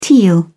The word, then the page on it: teal: British English